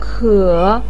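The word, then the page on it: ke: tone 3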